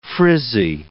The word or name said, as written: frizzy